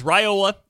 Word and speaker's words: Raiola: It is Raiola officially. That is how it's being pronounced.